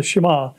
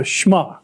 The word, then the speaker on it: Shema: And the first thing he said, well, it's not the Shema, it's the Shema. That's how we pronounce it.